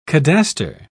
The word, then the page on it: cadaster: British English